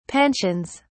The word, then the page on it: pensions: British English